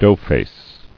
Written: Doughface

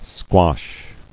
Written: Squashed

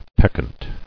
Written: Peccancy

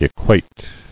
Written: Equated

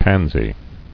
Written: Pansy

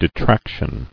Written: Detraction

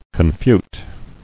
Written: Confuting

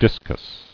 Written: Disci

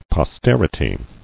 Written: Posterity

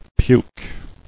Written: Puke

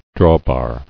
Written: Drawbar

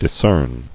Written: Discerner

